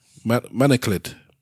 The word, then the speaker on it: manacled: And at the height of slavery, there were no white-seeming people, I'm not sure what that word is, manacled on the slave ships. So, excuse my ignorance for not knowing what that word is or means or how to pronounce it. Maniclad. – M-A-N-A-C-L-E-D. – Manacled.